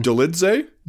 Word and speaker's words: Dolidze: Roman Dolidze. Did I say that right? How do you say it? – Dolidze? – Dolidze. Roman Dolidze went out there and put a citizen's arrest on Jack Hermanson.